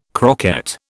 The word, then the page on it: croquette: British English